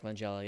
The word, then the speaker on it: Langella: Is it Langella? Frank Langella? – Frank Langella, yeah.